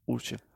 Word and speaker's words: Osier: Er det rigtigt udtalt efternavn egentlig? Jeg har altid Osier. – Det er sådan, jeg siger det.